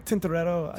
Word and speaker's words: Tintoretto: How do you say his name? – Tintoretto. – Tintoretto? Yeah. Do you have to say it with the accent like that? – I didn't realize I did it.